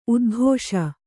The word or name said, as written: ಉದ್ಘೋಷ